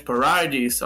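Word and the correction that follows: Paradis: acho que assim que se pronuncia, se eu errei, desculpa, por É, mas... Eu acho que, tipo, acho que é, fala como se fosse paraíso mesmo. Matt Paradise. Paradise.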